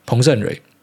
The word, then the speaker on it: 彭胜蕊: 然后另外请爱大帮我祝在纽西兰已经生日的台劳彭胜新还是彭胜蕊？那个字应该念蕊，彭胜蕊。